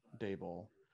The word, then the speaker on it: Daboll: Daboll.